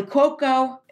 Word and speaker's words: Cuoco: Kelly Cuoco.